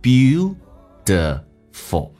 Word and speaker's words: beautiful: OK, 我们一起来学习如何让每个发音更加的精准。You are beautiful. 美式发音当中 T 趋向于发 D 这个发音 Beautiful.